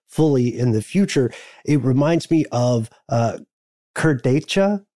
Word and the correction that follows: Kurdaitcha: which I may be mispronouncing, Kurdaitcha.